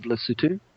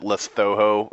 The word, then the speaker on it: Lesotho: If you're American like me and you don't know what country he's talking about, it's, we would probably pronounce it Lesotho or something like that. – Lesotho.